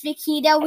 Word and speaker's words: Vaquita: Oh yeah, if we say Vaquita, just remember it's actually pronounced Vaquita. We made that mistake far too many times.